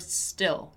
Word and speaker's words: still: Let's take the chance here to once again practice the pronunciation of the word still, right? Still. This letter I is not pronounced as in Portuguese, right? Remember that. It's still, We still need to finish establishing our marketing strategy.